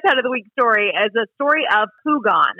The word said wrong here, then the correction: Pugin: and I hope I'm saying that correctly. – I was thinking Pugin. – Okay, Pugin.